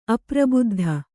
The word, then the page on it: ಅಪ್ರಬುದ್ಧ: aprabuddha